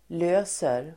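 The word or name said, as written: löser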